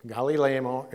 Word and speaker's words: Gamaliel: Gamaliel, something like that. It doesn't phonically look like that to me, but I'm not good at phonics anyway. So it's Gamaliel.